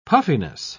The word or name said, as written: puffiness